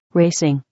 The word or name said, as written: racing